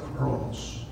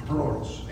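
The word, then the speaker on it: Cronos (χρόνος): Una de ellas, que su pronunciación correcta sería Cronos, Cronos en griego, sí, Cronos para nosotros, y Kairos o Kairos, no la otra palabra.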